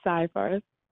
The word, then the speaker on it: Seyfarth: Yes, it's Seyfarth.